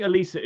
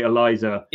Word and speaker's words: Elisa: I think Elisa is the correct way to, if you're going to pronounce that name, I think Elisa is the correct way, because I would say Eliza would be with a Z.